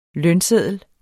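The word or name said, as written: lønsedler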